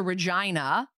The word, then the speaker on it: Regina: So she drives to Regina, rhymes with vagina.